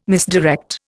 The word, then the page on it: misdirect: Indian